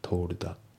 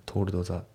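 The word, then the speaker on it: told: で、あと発音ですけど、トールドの D が抜けますね。トールダ、トールドザじゃなくてトールダ、トールダですね。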